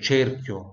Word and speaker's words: cerchio: Cerchio, cerchio, né? cerchio. No dicionário, em dicionários, é, já, já dei uma olhadinha, é, é com E mais fechado, tá? Cerchio.